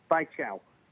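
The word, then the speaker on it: baijiu: And you pronounce Chinese whiskey bai chao.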